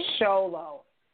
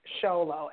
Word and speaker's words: Sholo: Solo. Solo. S-H-O-L-O.